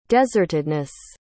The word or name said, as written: desertedness